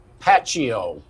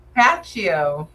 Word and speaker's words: iPacio: How did Everyday CPA come about, and tell us about your trademarked iPacio. Am I saying it correctly? – Pacio. – Pacio.